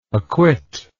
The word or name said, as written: acquitter